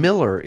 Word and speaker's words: Moeller: Miller is this fella's last name, but it's spelled M-O-E-L-L-E-R, right? – Good job.